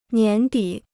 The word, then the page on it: 年底: nián dǐ